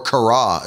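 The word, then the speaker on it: kara (χαρά): Now, the reason I brought you back to the interlinear is because in the Greek language, this is the word kara, or kara, as it's actually supposed to be pronounced,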